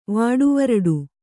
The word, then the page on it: ವಾಡುವರಡು: kannada